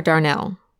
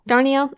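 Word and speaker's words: Darnielle: and that's Devil House by John Darniel or Darnell? – I would pronounce the I think John Darniel. – Darniel? Okay.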